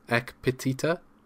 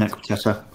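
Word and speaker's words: Ekpiteta: Forgive me if I say his name wrong. Ek Petita? – Yep, I love an Ek Petita.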